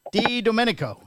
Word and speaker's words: DiDomenico: Anthony Didomenico.